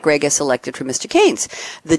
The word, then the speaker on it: Keynes: It's Keynes.